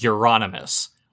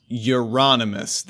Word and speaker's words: Euronymous: He used to go under the name as Destructor before finally deciding he would be known as Euronymous. Which I know I could kind of pronounce because it's like anonymous with Europe. Close enough, right? – Euronymous.